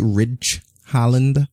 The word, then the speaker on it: Ridge Holland: Am I saying his name correctly? Ridge Holland? – Yep.